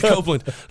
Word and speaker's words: Copeland: I said it rhymes with Oakland, Kenneth Copeland.